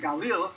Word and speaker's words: Galil: "in the Galil," which is how you say Galilee, "to the town of David called Bethlehem," which is how you say Bethlehem, "in Yehuda," which is how you say Judah.